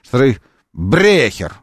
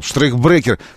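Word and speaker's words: штрейкбрехер: Штрейхбрекер. Так, я правильно. Штрейхбрекер. На «е», на «брекер» ударение. Вот сюда. Давай, правильно, значит, я вспомнил. Штрейхбрекер.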